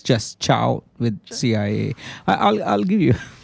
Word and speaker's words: chao: Or Spanish speakers, we just spell it C-H-A-O versus C-I-O-A.